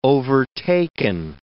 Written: overtaken

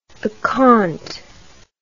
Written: accompt

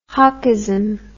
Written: hawkism

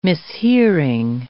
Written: mishearing

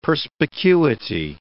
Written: perspicuity

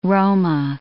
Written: Roma

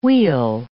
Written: wheal

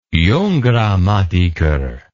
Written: Junggrammatiker

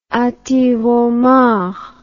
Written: antiroman

